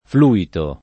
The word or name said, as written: fluitare